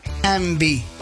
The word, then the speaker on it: Yngwie: Porque su nombre es Envy. Así se pronuncia, Envy.